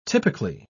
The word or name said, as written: typically